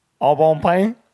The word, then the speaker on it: Au Bon Pain: Au Bon Pain? – Yeah, that's how you say it. Au Bon Pain.